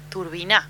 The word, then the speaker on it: Turbina: Turbina, porque tiene un acento en la A.